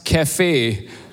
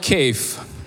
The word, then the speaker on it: cafe: I said to him, Pastor, it's pronounced cafe, not cave.